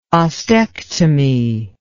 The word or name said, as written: ostectomy